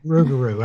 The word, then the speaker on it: Rougarou: And Pepe La Lugaroo. – Yeah. – That boy lives down south. He extra angry too. He's hot down there. – Rougarou. I said it wrong.